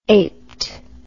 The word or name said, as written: aped